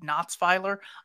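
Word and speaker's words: Natzweiler: In fact, you had said that there was a gas chamber program in a place called Knotsweiler, I think.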